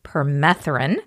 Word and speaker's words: Permethrin: And he politely told us that it is pronounced Permethrin.